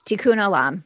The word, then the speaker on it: Tikkun Olam: So on your website, there's a Jewish phrase, and I don't think I'm going to probably say it right, but is it Tikkun Olam? – Tikkun Olam.